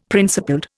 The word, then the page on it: principled: Indian